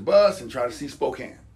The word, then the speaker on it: Spokane: But Spokane, it's pronounced Spokane, but it's Spokane to me.